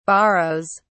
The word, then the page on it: borrows: British English